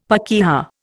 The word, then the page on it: pakeha: Indian